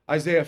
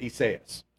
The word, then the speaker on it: Isaiah: This is a transliteration from the original language. We don't pronounce it Isaias, okay?